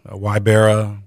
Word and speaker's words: Ybarra: Ybarra, yeah. Yeah, a lot of people say Ybarra, Ybarra, but it's Ybarra.